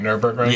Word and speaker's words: Nürburgring: And the Nürburgring record, I said that right, Nürburgring? – Yeah, okay, Nürburgring.